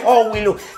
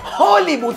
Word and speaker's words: Hollywood: Porque tú te la das a actriz Howie Luke. – Vas a empezar, maldita ignorante, no se dice Howie Luke. Se dice Hollywood, tierrúa, Hollywood. Dale, dale.